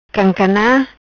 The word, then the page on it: Cancana: patua